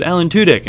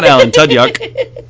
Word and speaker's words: Tudyk: And then said, Alan Tudyk, and I was like, yeah, that's him, not Alan Tudyuk.